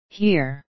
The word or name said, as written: here